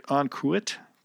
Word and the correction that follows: en croûte: So brie en croûte is basically cheese, brie cheese, wrapped in pastry dough and then baked.